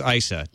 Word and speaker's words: Issa: So, Daryl Issa, is it Issa? I've been told how to pronounce this. – Issa. – It's Issa.